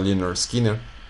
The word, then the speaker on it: Lynyrd Skynyrd: se pronuncia Lynyrd Skynyrd. Es una gran ayuda que nos enseñan a cómo pronunciar su nombre correctamente.